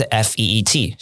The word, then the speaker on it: feet: 好，或是类似像是 fit 好跟 feet 好，一个是 f i t fit，一个是 f e e t feet。